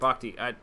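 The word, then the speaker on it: Bochte: Number three, Bruce Bochet. I don't remember. – Bochti. – I'm not pronouncing it. Bochti, that's right, Bochti.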